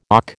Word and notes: och: American English

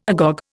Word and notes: agog: Indian

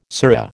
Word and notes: surra: American English

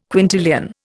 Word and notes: quintillion: Indian

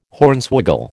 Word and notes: Hornswoggle: American English

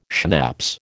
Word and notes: schnapps: American English

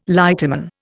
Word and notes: lighterman: Indian

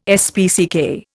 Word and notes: SPCK: Indian

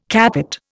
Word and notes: capot: Indian

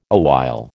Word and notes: awhile: American English

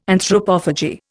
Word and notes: Anthropophagy: Indian

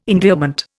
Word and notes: endearment: Indian

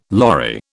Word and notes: lorry: British English

